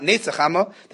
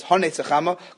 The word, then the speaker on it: Haneitz HaChama: It's not Neitz HaChama. The proper pronunciation is Haneitz HaChama, coming from the word Haneitz, which means to bud.